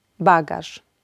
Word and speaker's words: bagaż: Zeszyt, lekarz, bagaż. Zwróćcie proszę uwagę na to, że jeśli litera Z z kropką albo RZ znajduje się na końcu wyrazu przed pauzą, wymawiamy ją jako sz.